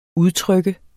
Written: udtryk

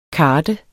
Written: karde

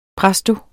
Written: presto

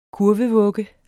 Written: kurvevugge

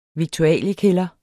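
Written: viktualiekælderes

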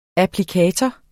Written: applikator

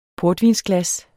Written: PORTVINSGLAS

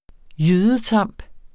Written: jydetamp

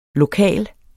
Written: lokale

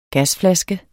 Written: gasflaske

